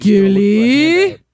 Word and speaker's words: Gilly: Well, you're not saying her name right. – She's Gilly? – Better.